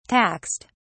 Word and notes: taxed: British English

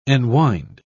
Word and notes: enwind: British English

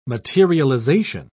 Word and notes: materialization: British English

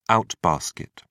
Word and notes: out-basket: British English

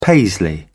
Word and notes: paisley: British English

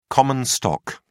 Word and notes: common stock: British English

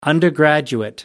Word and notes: undergraduate: American English

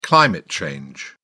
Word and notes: climate change: British English

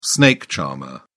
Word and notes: snake charmer: British English

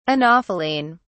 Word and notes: anopheline: British English

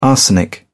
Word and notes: arsenic: British English

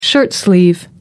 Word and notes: shirtsleeve: American English

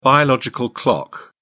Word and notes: biological clock: British English

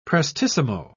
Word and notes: prestissimo: British English

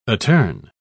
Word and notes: attorn: British English

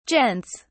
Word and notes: gents: British English

